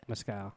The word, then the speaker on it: mezcal: Mezcal.